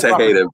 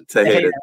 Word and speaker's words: Tejeda: It's Tejeda, bro. Tejeda.